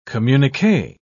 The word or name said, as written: communique